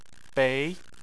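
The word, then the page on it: bei: tone 2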